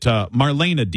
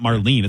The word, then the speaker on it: Marlene: Is it Marlena Dietrich, right? It's not Marlene. It's spelled Marlene.